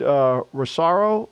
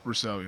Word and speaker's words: Rousseau: OK, you know, they drafted Rosario. I think that's how you say his name. – Gregory Rousseau from Miami. Yep. Rousseau.